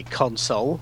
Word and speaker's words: console: The thing is that now, obviously, I say console because I mean I speak the Queen's English, and I'd not noticed that you pronounced it differently.